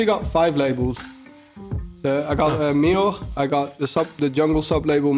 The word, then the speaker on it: Mior: Mior, yeah. Okay, that's actually correct. Yeah, most people say Mior, but it's pronounced Mior.